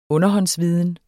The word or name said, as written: underhåndsviden